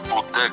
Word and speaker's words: Wood: Sì, è una scelta naturale, nel senso che Michelangelo è chiaramente il mio nome di battesimo, e Wood, che si scrive con la V normale O-O-D finale, è un cognome strano, me ne rendo conto, ma è semplicemente il cognome della mia mamma.